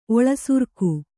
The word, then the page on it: ಒಳಸೆರಗು: oḷaseragu